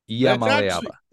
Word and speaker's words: Iamaleava: Like I think it's six syllables. So I went with Iamaleava. – Iamaleava. That actually, I think that's it, to be honest with you.